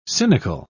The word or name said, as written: cynical